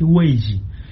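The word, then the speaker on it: Weiji (危机): do ponto de vista da língua mandarim, portanto, língua oficial da China, pronuncia-se Weiji.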